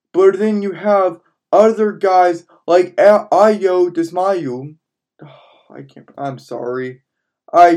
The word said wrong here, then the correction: Dosumu: Ayo Dosumu.